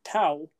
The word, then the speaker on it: tau: So, you know, neutrinos, you know, most people would be familiar with them as there's three different types, electrons, muons, and tau is how it's pronounced. It's actually spelled T-A-U, I believe.